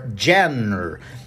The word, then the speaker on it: genre: Mas muita gente vai olhar ali sem saber como é que se pronuncia essa palavra, vai querer falar genre or genre. É uma palavra estranha mesmo. Então a pronúncia ela sai um pouquinho da que a gente está acostumado a ouvir em inglês, que é genre.